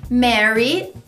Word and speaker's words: merry: Merry tem a mesma pronúncia de marry de casar.